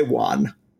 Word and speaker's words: wan: When I say wan, it sounds like it should be spelled W-O-N, but that's one. And what W-A-N should be pronounced wan, but that's not how it's pronounced.